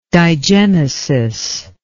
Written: digenesis

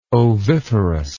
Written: oviferous

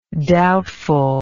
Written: doubtfulness

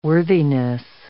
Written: worthiness

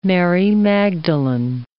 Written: Mary Magdalene

